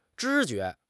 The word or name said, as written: 知觉